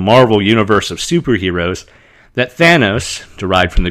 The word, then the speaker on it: Thanos: repeatedly has his name mispronounced by the heroes of the film. Brown says they constantly drop the N, calling the villain Theos, or Theos, again, from the Greek, literally calling him God.